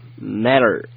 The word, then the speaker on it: matter: 嗯，但是在美式英语里面呢，它有一个发音的特点就是 t 的不分。 – 嗯。 – 啊，所以在这儿呢，你可以听到我是把它读成了 matter，哎，特的不分，你只要这个很含糊的发这个音就可以了。